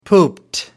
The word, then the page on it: pooped: British English